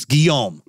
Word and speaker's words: Guillaume: It's Guillaume.